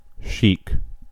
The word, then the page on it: sheik: /ʃeɪk/